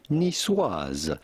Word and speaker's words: niçoise: In French, this is normally pronounced niçoise. Niçoise.